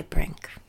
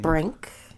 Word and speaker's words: Brink: And, yeah, you can contact me through my Instagram, Latch by Brink. – How do you spell that? – Latch. – How do you spell latch? – L-A-T-C-H. – Okay. – By, B-Y. – And last name? – Brink. – B-R-I-N-K.